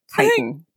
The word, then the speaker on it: chitin: And then not until I said it out loud here on the podcast was I like, nope, that's kitten. That's a small young cat. There's no way that that's how to pronounce it. So it is indeed chitin.